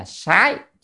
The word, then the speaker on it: sái: Chữ sát này ở đây có nghĩa là phải đọc âm là sái chứ không đọc sát là sai. Vừa rồi thì đang đọc sát đúng không, nó là thẻ thói quen thế, và tra từ điển cho thấy âm đọc là sái,